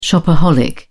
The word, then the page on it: shopaholic: British English